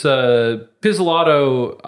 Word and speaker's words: Pizzolatto: It's Pizzolatto, I believe.